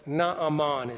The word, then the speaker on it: Naaman: The Hebrew, when it puts two A's together, pronounces both. Naaman is his name.